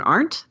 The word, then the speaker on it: Arndt: My name's Susan Arndt. That's how you pronounce my last name, Arndt, just like Arnott.